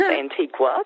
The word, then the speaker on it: Antigua: It is pronounced Antigua. sorry. Everyone thinks it's Antigua because of the spelling.